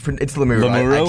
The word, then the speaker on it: Lemieux: That's how it doesn't look like it'd be pronounced that way. – Maverick Lemieux. – It's pronounced maybe. – It's Lemieux. I checked the pronunciation.